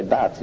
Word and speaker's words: Bath: It's spelled B-A-T-H, but if you're wealthy, you don't say Bath, you say Bath.